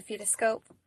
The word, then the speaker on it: fetoscope: I had the urinalysis strips, the Doppler, the Fecto Spectroscope is, I think is how you say it um, to hear the baby's heart tones through like a stethoscope. I stethoscope? – a fetoscope.